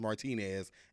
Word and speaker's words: Martinez: But my Facebook is definitely Isaias Martinez, and that's my first name, everybody. And it's spelled Isaias, and my last name, Martinez, MartinEz.